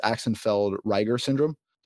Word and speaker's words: Axenfeld-Rieger syndrome: And you're quite open with it, which is the fact that you're legally blind from a condition, and please correct my pronunciation, but I believe it's Axenfeld-Rieger syndrome. Did I get that correct? – Yeah.